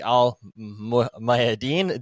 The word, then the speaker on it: Al Mayadeen: Al Mayadeen.